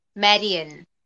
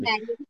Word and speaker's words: Marian: Okay, so how do you say yours properly? – Marian. – Marian. Marian, am I like saying correctly? – Marian from Robin Hood.